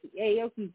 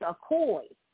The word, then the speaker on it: Aoki: I was saying Aoki's name, it's a koi, not Aoki.